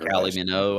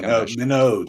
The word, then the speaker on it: Kylie Minogue: It's actually I don't know if I'm saying her name right, but he does one with Kylie Mignon. I don't know how you say her. – Kylie Minogue, I think. – Minogue, that's it.